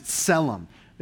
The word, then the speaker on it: tselem: The Hebrew word for image is tselem. It's spelled T-S-E-L-E-M. So the T is a little bit silent, tselem.